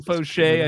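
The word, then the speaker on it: Faucher: Dr. Foucher at three. – He's getting three saves last week. Calvin Fauché, I should pronounce his name properly.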